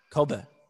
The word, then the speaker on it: Kobe: You showed us a picture of the other Chabad Shliach in Kobe, and did I say that right? – Kobe.